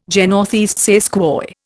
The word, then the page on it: je ne sais quoi: Indian